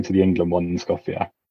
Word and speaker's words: Skopje: Is it Skopje? Is that how you pronounce it? – Skopje, yeah.